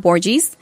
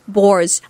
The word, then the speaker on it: Borges: Her last name is not Borges. It's Borges. Like gorgeous, she said. Like Borges. Borges.